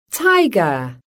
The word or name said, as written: tiger